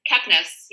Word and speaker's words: Kepnes: And I don't even know how to say her last name. Kneps? – Kepnes, yeah. – Kepnes.